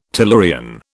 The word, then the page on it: tellurian: British English